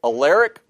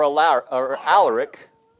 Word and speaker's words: Alaric: I've heard it pronounced two ways, Alaric or Alaric, the German invader and his armies, sacked Rome.